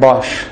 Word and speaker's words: Bosch: Alright, imagine you buy a dishwashing machine from a reputed company like Bosch. Is that how it's pronounced? Yeah? Right?